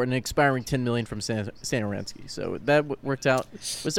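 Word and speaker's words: Satoranský: Satoransky. Is that how you say it? – Satoransky.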